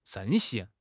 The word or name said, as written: 盛行